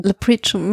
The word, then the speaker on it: Leprechaun: Also halt den äh, Leprechaun mit seinen Top, mhm. ich weiß mhm. gar nicht, ob ich den Namen jetzt richtig ausspreche. Oh. Leprechaun, ja.